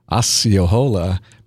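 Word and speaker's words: Osceola: And so the pronunciation is actually Asiola, because when he drank the black drink, he sucked it down with such vigor that he yelled out, "Asi Yahola," and the name stuck.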